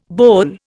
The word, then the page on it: boll: Indian